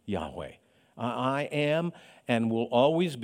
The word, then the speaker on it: Yahweh: But the name of God, it's probably pronounced Yahweh in English.